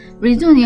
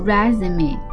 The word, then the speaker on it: resume: resume 有两种发音啊，如果读 resume，它就是表示简历啊。 resume 呢，读 resume 呢，它就是一个动词，表示， to start again，就再一次出发或再一次开始。